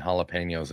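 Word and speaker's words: jalapeño: Angel makes this amazing three cheese baked mac and cheese with bacon bits and jalapenos in it, like real bacon Ooh, bits.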